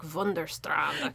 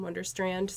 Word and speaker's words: Wunderstrand: Okay, but yeah, I actually just pronounce it Alex in Wunderstrand, so very American.